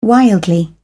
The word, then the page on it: wildly: British English